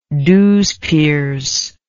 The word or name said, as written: douzepers